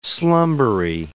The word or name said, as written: slumbery